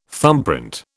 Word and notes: thumbprint: British English

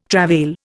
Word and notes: travail: Indian

